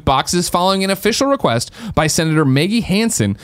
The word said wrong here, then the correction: Hassan: a Democrat from New Hampshire. – Maggie Hassan. – Damn, thank you very much. I did totally butcher that.